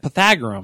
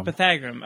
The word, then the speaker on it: Pythagorean: Is that how you say it? – Pythagorean. – Pythagorean. Okay.